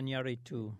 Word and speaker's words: Iñárritu: No, the director of The director, Alejandro Iñárritu. – Yeah, Iñárritu and Cuarón, is that they don't make Latino movies anymore because of exactly what James was talking about.